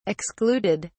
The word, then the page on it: excluded: British English